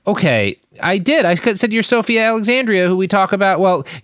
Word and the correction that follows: Alexandra: First of all, pronounce her last name right. – Sophia Alexandria. – Nope. – What? Alexandra, sorry.